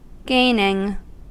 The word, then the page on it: gaining: American English